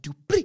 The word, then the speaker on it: Dupree: Just like, it's, no, you're saying it wrong. It's Max Dupree.